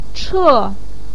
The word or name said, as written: che4